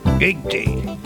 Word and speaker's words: guilty: Se escribe G-U-I-L-T-Y. Yo sé que el final sería Chai, pero busqué ahora y no exactamente. A ver cómo se pronuncia Gitchi. Que ya voy a buscar el significado. A ver, lo tengo acá. – Guilty. Guilty.